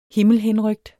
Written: HIMMELHENRYKTE

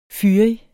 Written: fyrig

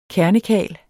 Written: KERNEKARLS

